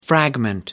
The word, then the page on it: fragment: American English